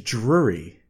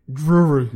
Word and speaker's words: Drury: And it's Drury. – Drury. – Not Drury.